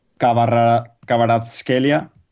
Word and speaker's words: Kvaratskhelia: El georgiano, que a ver si me sale el nombre, Kvaratskhelia. No sé si lo he dicho bien o no, que me perdonen los amigos georgianos. Tú piensas en la V como si fuese una U.